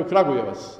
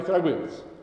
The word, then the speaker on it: Kragujevac: Dolaze mi studenti, u posljednje vrijeme bude studenata iz, pošto je Kragujevac rasadnik talenata za glumu, i prvo po čemu ih prepoznam, oni kažu Kragujevac. Dakle, to je sitnica koju i sad već može Jaro da čuje. Dakle, Kragujevac. Sad kako silazim akcentom, u stvari je Kragujevac.